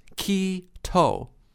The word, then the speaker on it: Quito: The capital of Ecuador is Quito, pronounced Quito.